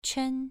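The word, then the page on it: quan: tone 2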